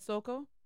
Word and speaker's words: Saoko: You said Soko. – S-A-O-K-O. – Yeah. You said it right.